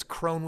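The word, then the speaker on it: Krone: It's Chris with a K, and it's Crone with a K.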